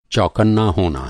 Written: चौकन्ना

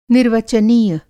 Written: निर्वचनीय

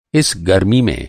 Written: गरमी

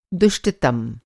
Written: दुष्टतम

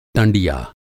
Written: टँडिया

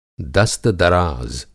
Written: दस्तदराज़

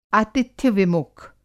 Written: आतिथ्य-विमुख

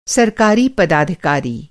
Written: पदाधिकारी

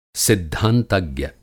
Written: सिद्धांतज्ञ

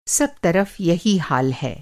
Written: तरफ़

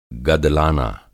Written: गदलाना

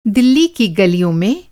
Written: गल

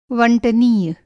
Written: वंटनीय

